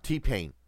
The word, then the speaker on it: T-Pain: T-Pain. – T-Pain? – Pain, uh-huh. I said it right. – As in, ouch.